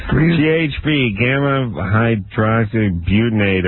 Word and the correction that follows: hydroxybutyrate: How do you say it, though? I screwed that up. – Gamma. – And it's a drug. – Gamma? – Hydroxybutyrate.